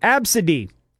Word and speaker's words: ABCDE: Some of the top votes from Reddit are the name ABCDE, pronounced Absidy.